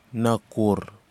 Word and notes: nakhour: kwamera

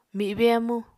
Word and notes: mibe amu: Apatani

